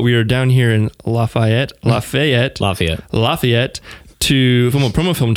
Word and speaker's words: Lafayette: which not all of our podcasts are live, but Larry, we are here in Lafayette. Am I saying that right? Lafayette? – Yeah, depending on where you're from. – How do you say it? – I say Lafayette.